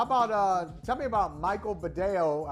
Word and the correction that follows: Badejo: Yeah, Badejo is how he pronounced Badejo.